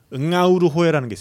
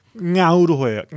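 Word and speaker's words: Ngauruhoe: 이게 통가리로라는 거를 통가리로 다 뒤에 응아우르호에라는 게 있습니다. 여기 이제 마오리 이름이어서 좀 발음이 응아우르호에, 응아우르호에 NG로 시작하는 발음입니다.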